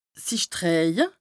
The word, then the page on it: sich drëhje: Bas-Rhin; Strasbourg